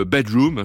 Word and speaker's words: BDR2M: Il y aura également Bedroom, ça s'écrit BDR2M, et puis les Liminianas.